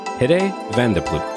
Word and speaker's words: Hidde van der Ploeg: Today I'm excited to bring you the developer behind the AI assistant Apple Watch app, Petey, Hidde van de Ploeg.